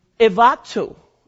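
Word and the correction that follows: enduo (ἐνδύω): Evato is what, how do you say it? – It's enduo. – Enduo. Thank you.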